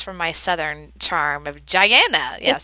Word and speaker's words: Guyana: She was born in Guyana, which is in South America. – Oh my gosh. See, I apologize. – It's that little country right above Brazil.